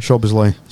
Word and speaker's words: Szoboszlai: Szoboszlai. – Szoboszlai.